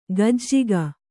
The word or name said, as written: ಗಜ್ಜಿಗ